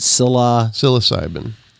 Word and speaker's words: Psilocybin: Psilocybin. – Psilocybin, not only being good for you microdosing, but reversing neuropathy, which everybody goes through at some point.